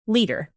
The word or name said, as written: litre